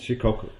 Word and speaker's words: Shikoku: Shikoku, is that how you say it? – Yes, Shikoku.